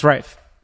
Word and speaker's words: Ralph: That's Ralph. – Oh, is it Ralph? Oh, yeah, it's Ralph. – That's how you pronounce it.